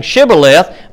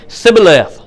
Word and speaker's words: Shibboleth: Instead of saying Shibboleth, they couldn't pronounce that sh, so they were just going to say Sibboleth.